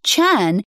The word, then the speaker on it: Chan: Now, just in case anybody's taking notes, your name is spelt N-I-K-K-I, right? – Yes, that's right. Nikki with two K's and Chan is just C-H-A-N.